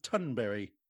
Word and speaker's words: Thunberg: And Greta Thunberg, it shows how arrogant the world is because you actually pronounce her surname Tunbury, which sounds to me like a very sort of home counties kind of surname, but apparently that's how the Swedes pronounce it. – Right, so the G is actually a Y.